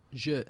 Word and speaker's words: zhi: So this is the word, it's zhi, is that how you pronounce it? – Zhi. – Z-H-I. – Yeah, Z-H-I, zhi.